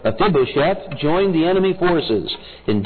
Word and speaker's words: Mephibosheth: Mephibosheth would be the proper pronunciation.